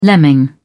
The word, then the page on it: lemming: British English